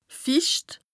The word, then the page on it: ficht: Bas-Rhin; Strasbourg